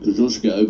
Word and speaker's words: Kosciuszko: But of course, the other significant name in all of this, of course, is Kosciuszko, or for our Polish friends, they'd be much closer to pronouncing it Kosciuszko, but we'll let that one rest for a moment.